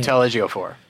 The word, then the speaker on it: Chiwetel Ejiofor: Chiwetel Ejiofor. – Chiwetel Ejiofor.